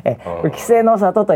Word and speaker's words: 稀勢の里: 「稀、え、勢、の里」と読みます、